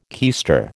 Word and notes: keister: American English

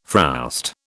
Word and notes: frowst: British English

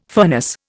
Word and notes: furnace: Indian

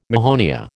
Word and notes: mahonia: American English

